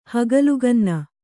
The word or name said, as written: ಹಗಲುಗನ್ನ